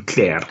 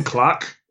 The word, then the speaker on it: Clark: It's not pronounced Jim Clare. It's Jim Clark, actually, but there you are.